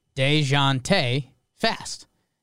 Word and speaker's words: Dejounte: But so Paul said, I learned to pronounce DeJounte's name by saying DeJounte fast. You get a sort of Z sound at the start of the second syllable.